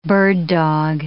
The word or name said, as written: bird-dog